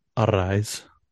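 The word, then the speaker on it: Arráez: I believe his name is pronounced Arise.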